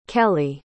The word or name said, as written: kelley